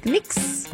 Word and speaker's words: Knicks: Rafa, tô atualizando aqui o nosso feed, a gente volta a falar de esporte, porque Knicks e Seleção Brasileira, eu falei certo? Knicks? – Falou certinho. É assim que pronuncia? Falou certinho, falou certinho.